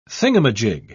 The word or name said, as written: thingamajig